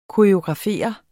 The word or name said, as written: koreografere